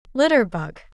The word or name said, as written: litterbug